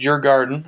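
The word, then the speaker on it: Djurgården: Yeah, no, the team in Stockholm is it's pronounced your garden, as in like your garden, Oh, wow.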